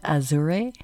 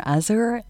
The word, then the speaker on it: Azure: Here's some takeaways from my conversation with the editors at Azure, or Azure, it's as you wish is how you pronounce it.